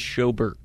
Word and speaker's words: Schobert: Well, County Swami, when Joe got cut, is it Schobert or Schubert? – I believe it is Schobert.